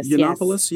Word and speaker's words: Yiannopoulos: Milo Yiannopoulos, I think is how you pronounce his last name. – Yiannopoulos, yes.